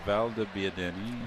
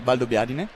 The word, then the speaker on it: Valdobbiadene: Valdobbiadene. – Valdobbiadene.